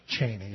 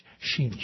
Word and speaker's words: Cheney: Because that's how you say his name. It's not Cheney. It's Sheeny.